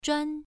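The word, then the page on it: zhuan: tone 1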